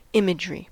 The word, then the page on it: imagery: American English